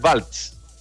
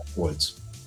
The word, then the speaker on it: Waltz: Está mal pronunciado, ya, Waltz. Ya, es bueno aprender. Waltz.